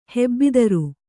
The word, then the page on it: ಹೆಬ್ಬಿದಿರು: hebbidiru